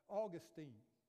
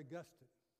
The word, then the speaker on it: Augustine: And I was reading this the other day by Augustine or Augustine. It just depends on which syllable you put the emphasis, okay? So some people say Augustine.